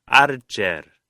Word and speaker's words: Archer: T-I-M-O-T-H-Y, Timothy, Archer, A-R-C-H-E-R.